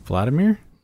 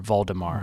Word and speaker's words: Valdimar: Vladimir? – Nope. I thought it was, but it's spelled V-A-L-D-I-M-A-R. Valdemar. – Valdemar.